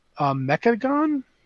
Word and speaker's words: Mechagon: The two zones are Nazjatar and Mechagon. Am I saying that right? – Yeah, Mechagon.